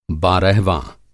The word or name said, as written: बारहवाँ